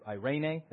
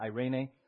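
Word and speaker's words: Irene (εἰρήνη): Irene. That's how you pronounce it. Irene.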